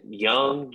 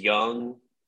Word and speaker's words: Jung: Isn't it Young? Jace Jung? It's the J, I guess, like with the Spanish pronunciation.